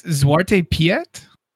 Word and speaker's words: Zwarte Piet: So it's Zwarte Piet? – Sure. – Black Peter, that's who it is. Yes. It's Black Peter. – Yeah. Zwarte Piet. Is how that's pronounced.